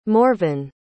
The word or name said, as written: morven